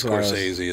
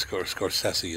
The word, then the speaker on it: Scorsese: Yeah, but everybody says Scorsese. It's Scorsese is how you say his name.